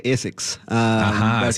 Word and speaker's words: ASICS: A-S-I-C-S. – In Asia, we say ASICS.